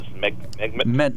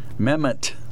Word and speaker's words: Mehmet: Mehmet. – Well, how do you pronounce it? – Mehmet.